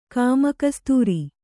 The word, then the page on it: ಗೋಮುಖಸೂತ್ರ: gō mukha sūtra